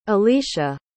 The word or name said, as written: alecia